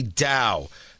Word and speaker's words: dhow: CENTCOM Navy forces conducted a nighttime seizure of what's known, I believe it's pronounced a DHOW, D-H-O-W. This is a type of sailing vessel from the Indian Ocean with one or more masts on it. I had to look it up. D-H-O-W. It's how it's pronounced.